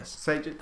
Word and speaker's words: Sudeikis: Is that how you pronounce Jason it? Jason Sudeikis.